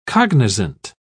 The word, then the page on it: cognisant: British English